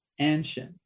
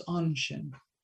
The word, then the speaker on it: Anshin: Well, it's really typically American to pronounce that first A hard, Anshin, but really it's a soft A, it's Anshin.